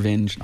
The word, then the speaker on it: Vinge: That's how you pronounce his name, Werner Vinge, author I like a lot. – Okay. – Yes. – And it's V-I-N-G-E.